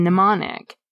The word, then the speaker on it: mnemonic: T-E-R. That same thing happens with the Greek root M-N-E-M, meaning mind. The M is silent in mnemonic, but in the word amnesia, it gets pronounced as the end of the syllable am, am at the beginning of amnesia.